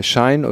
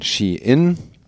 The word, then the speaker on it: Shein: Äh, Schein oder Nichtschein. Ähm, äh, das ist natürlich nicht korrekt ausgesprochen, weil der Konzern, äh, über den es geht, um den es geht, äh, wird eher ausgesprochen Shein.